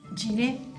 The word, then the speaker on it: Giner: Sabéis que la Institución Libre de Enseñanza era una institución que se fundó allá por 1876 por Francisco Giner de los Ríos, un catalán. ¿No se dice Giner? ¿Cómo se dice? – Giné, bueno.